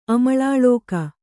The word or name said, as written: ಅಮಳಾಳೋಕ